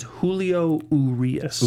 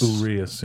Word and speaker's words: Julio Urias: Pronunciation I got from the Oklahoma City guys was it is Julio Urias. – Urias, yeah.